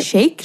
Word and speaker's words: Shayk: Irina Shayk, am I saying that right? – Shake? – Shake? – She Shike? Is shake.